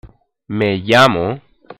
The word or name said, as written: me llamo